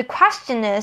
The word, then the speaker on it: question: 好，The question is，这边是最后要注意的地方。 Question，其实呢，question 是一般的一开始的发音。 Question，this question。 Q-U-E-S, quest, 然后再 chen, C-H-Ch, question, 可是在这边它的 C-H 后面的音发得非常的小声，就会变成 the question, 大家可以试试看。